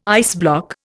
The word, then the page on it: iceblock: Indian